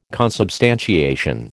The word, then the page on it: consubstantiation: American English